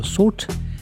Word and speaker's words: Sault: Salt. Salt, ça s'écrit S-A-U-L-T.